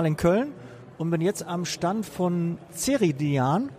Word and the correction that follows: Ceridian: Ziridian. Ziridian. Ziridian. – Ja, okay, das wird anders ausgesprochen, Entschuldigung.